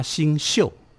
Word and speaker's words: 星宿: 我们其实那个时候叫做二十八星宿。这个宿啊，就是住宿的宿，宿舍的宿。 – 对对对，我们要念念成星宿。